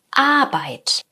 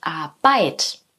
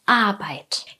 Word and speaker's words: Arbeit: Genauso wichtig ist hier auch die richtige Betonung. Wir betonen vorne. Arbeit, wir sagen nicht Arbeit, sondern Arbeit.